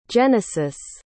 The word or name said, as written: Jenesis